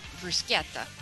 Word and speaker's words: bruschetta: Actually bruschetta. Say it right.